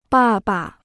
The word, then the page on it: 爸爸: bà ba